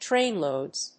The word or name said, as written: trainloads